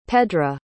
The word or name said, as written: pedra